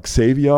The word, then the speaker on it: Xavier: Es war nicht nur, der heißt ja Xavier, ne? Also X-A-V-E-I-R. Und das spricht man Xavier aus, aber ganz viele nannten ihn Xavier,